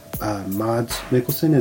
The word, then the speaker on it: Mads Mikkelsen: Enter Pusher, which is a low-budget mockumentary-style flick from 1996, set in Copenhagen and starring a very young Mads Mikkelsen. Is that how you pronounce it? – Yeah, I would say so.